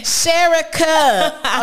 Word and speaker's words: Sherika: Sherika.